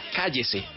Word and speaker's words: KYC: estos días Bubblegum nos presenta nueva canción, nos presenta Cállese, que se escribe K-Y-C, tres letras que dan título a este nuevo sencillo de Bubblegum.